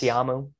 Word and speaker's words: Ta'amu: Well, from Kellen Mond and Tip, how do you say his name? Ta'amu? – Jordan, yes. Ta'amu.